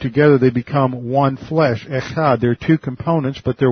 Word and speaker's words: echad: Now this word one is the Hebrew word echad. Echad. You can spell that in English, E-C-H-A-D.